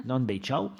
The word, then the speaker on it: Nanbei Chao: on a englobé aussi la période dite Nanbei Chao. Si je fais des grosses erreurs de prononciation, tu me corriges. – C'est parfait. – Nanbei Chao.